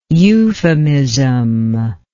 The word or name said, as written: euphemiously